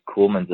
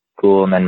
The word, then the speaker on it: Ceulemans: And is it two syllable or three? Is it Ceulemans or Ceulemans? – Just Ceulemans is fine. Just Ceulemans, man. That's how I tell people it is.